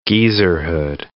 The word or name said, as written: geezerhood